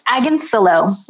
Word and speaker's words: Agoncillo: It's Agoncillo. Rhymes with pillow.